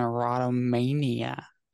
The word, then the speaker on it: erotomania: There are other conditions that involve dysfunctional love, like dependent personality disorder, sex addiction, and erotomania.